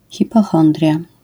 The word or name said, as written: hipochondria